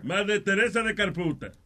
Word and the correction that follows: Calcuta: De Calcuta. Pero Dios mío, Calcuta. Calcuta. Pronuncien bien la vaina.